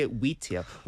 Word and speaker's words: wheat: Wheat. W-H-E-A-T. – Wheat. – 네. Yeah, yeah. Wheat, wit. Wheat, wit.